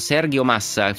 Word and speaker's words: Sergio Massa: Vorrei chiedere innanzitutto un ritratto di questo Sergio Massa. Forse sbaglio la pronuncia, correggimi. – No, è giusto. Sergio, lui è di origine italiana, Sergio lo si può pronunciare anche all'italiana, Sergio in spagnolo.